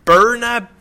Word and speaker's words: Byrna: I'm struggling with pronouncing her name, but please forgive me. – What's that first name? Sorry. – Birna Brilliant. So B-Y-R-N-A.